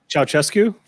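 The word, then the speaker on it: Ceaușescu: They're from Romania, and they got a Romanian gold, and then they came here and defected from the fucking, what's that, Kukachow? I can never say his name right. – Hold on, wait. – Oh, Ceausescu? – Ceausescu, thank you.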